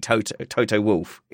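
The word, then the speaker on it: Toto Wolff: There's literally no controversy about this. It is definitely Toto Wolf.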